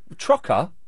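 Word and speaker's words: troca: We spell it T-R-O... – What? – C-A. – What, hang on, T-R-O's... – Yeah, I mean... – Trocker? – Yeah, that's what it means. That's how we say it in Brazil.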